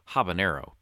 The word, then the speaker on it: habanero: I really respect the fact that you honor the enye with your pronunciation of habanero, because the enye is not in habanero. It's just a regular N, and you pronounce it correctly.